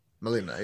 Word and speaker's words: Melina: Is it Melina? – Yeah. Melina, yeah.